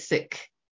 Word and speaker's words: Sikh: Some people call it Sikh, the Sikh religion, but got she it, it. – she pronounces it Sikh.